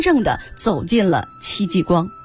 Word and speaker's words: 戚继光: 今天我们要聊聊戚继光啊，其实我刚才发音还错了，戚继光。